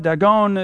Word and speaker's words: Dagon: Now, Dagon, we call it Dagon, is the pronunciation of it, was the god that was the primary god of the Palestinians, as far as we understand it.